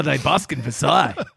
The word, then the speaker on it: Versailles: Versailles. That's how you say it in French.